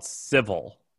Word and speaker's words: Civvl: It's called Civil. It's spelled really dumb. Civvl.